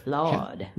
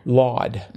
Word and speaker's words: Lawd: So I was sort of natticed at Nui, and I had trouble with ID number, and I finally figured out that it could be Laud, L-A-W-D for Laud. – Laud. – Mm-hmm. – I guess that's a southern pronunciation or something? – Yes.